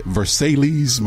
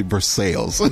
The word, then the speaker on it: Versailles: I said Versailles, Missouri. – Versailles. – It's actually Versailles.